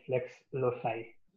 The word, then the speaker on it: Lex Loci: Okay, my Twitter handle is FLEX LOCI, Lex Loci. That's how you pronounce it.